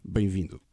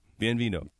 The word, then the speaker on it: Bem-vindo: How do you say welcome in Portuguese? – Bem-vindo. – Bem-vindo. – That's correct.